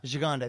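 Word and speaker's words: Gigandet: Gigande, that's how you say it.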